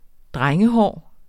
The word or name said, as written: drengehår